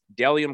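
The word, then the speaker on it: Bdelliom: My next sponsor is Dellium Clothing. That's B-D-E-L-L-I-O-M. Pronounced Dellium.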